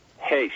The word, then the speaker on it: Heche: Heche.